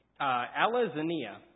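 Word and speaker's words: alazania (ἀλαζονεία): So there's a Greek synonym for pride, and it's pronounced alazania, and this means to make empty boasts, which leads me to my first point, and that is that pride isn't humble.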